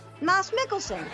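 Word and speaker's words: Mads Mikkelsen: Am I saying his name wrong? Isn't he from Copenhagen? – I don't know. – Isn't it Moss? All right. You know, another round, that actor. Moss Mikkelsen.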